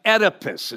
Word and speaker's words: Oedipus: One of the Greek tragedies was about a guy by the name of Oedipus, is how you pronounce this guy's name.